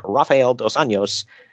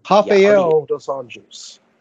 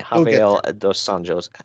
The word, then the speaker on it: Rafael Dos Anjos: speaking of MMA, UFC officially announced UFC 197 for March 5th, headlined by featherweight champion Conor McGregor versus lightweight champion Rafael Dos Anjos for RDA or Rafael Dos Anjos. – Oh, excuse me, Rafael Dos Anjos. I've always heard it pronounced the other way, so I knew it was.